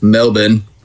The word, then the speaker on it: Melbourne: One section of Melbourne. Hope I'm saying that right. – It's Burn, not Born.